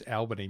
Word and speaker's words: Albany: Oh, it's Albany, mate. We're in WA now. – Is that? – Yeah. – Ah. – We always know when you're not from WA when you call it Albany, because we call it Albany.